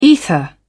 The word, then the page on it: ether: British English